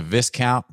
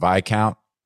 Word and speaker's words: viscount: Calling it a viscount, by the way. And yes, I understand everyone who felt the need to message me, but I know it's pronounced viscount, I guess.